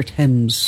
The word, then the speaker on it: Thames: How you pronounce that river. – It's the River Thames.